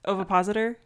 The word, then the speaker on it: ovipositor: help me with it. Ovipositor. Ovipositor. A depositing ovids. – Thank you. Is that okay? That's how you say it.